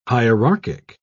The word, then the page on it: hierarchic: British English